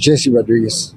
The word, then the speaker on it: Jesse Rodriguez: My name is Jesse Rodriguez.